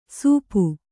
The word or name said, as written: ಸೂಪು